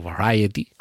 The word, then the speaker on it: Variety: Variety, o Variety, como se pronuncia,